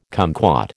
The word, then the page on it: kumquat: American English